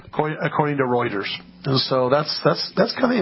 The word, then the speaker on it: Reuters: Apparently, according to Reuters, Reuters. – How many times do I say that wrong? – You say that wrong every time. – Just a couple of hundred, yeah. – Reuters.